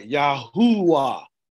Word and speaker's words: Yahuwah: So the one that begins is a short ah, and the one the T is a long ah. So it's like Yahuwah is how we pronounce Yahuwah, Yah's name.